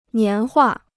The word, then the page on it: 年画: nián huà